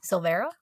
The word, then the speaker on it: Silvera: How do you pronounce that? – Silvera. – That's what I thought. I was going to say saliva.